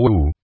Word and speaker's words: wu: El maestro respondió: uno esta sola palabra wu, además de ser un mantra que se pronuncia con doble u como imitando el sonido del huracán, es también por sí mismo un koan.